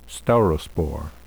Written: staurospore

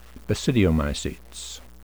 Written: basidiomycetes

